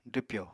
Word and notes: dwpyò: apatani

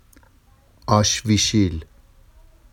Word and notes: ɑʃviʃil: Vafsi

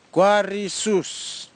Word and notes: kwarisus: Kwamera

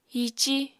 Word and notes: hiicí: Apatani

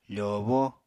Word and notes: fòobó: apatani

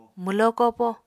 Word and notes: mwlo kopo: Apatani

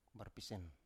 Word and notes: barpisen: Biak

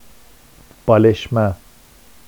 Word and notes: bɑleʃma: Vafsi

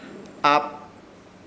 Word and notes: Hap: Ishir Chamacoco

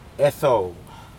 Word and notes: edou: Aneityum

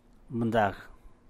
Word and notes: bti’ux gel: San Mateo Macuilxochitl Zapotec

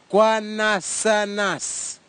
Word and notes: kwanasanas: kwamera; Tanna

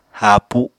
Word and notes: haapu: Apatani

